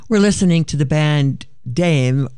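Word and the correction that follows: Dàimh: It's actually, you pronounce it in Scotch Gaelic, it's pronounced dive, but it's spelled D-A-I-M-H.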